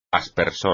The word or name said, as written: aspersor